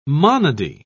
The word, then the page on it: monody: British English